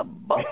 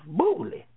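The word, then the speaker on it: Boulé: It's pronounced Bully. – Mm-hmm. – Oh, okay. Then Bully.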